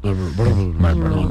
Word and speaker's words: Marlboro: Oye, sí, es que una R es que nunca suena. – No se pronuncia. – ¿No se pronuncia? – Marlboro. Nadie dice Marlboro.